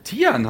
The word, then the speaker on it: Tijan: Tijan haben wir doch gesagt. Wir haben Tijan gesagt. – Tichan. – Entschuldigung, achso, Entschuldigung, wir müssen es aussprechen wie das deutsche Töreton.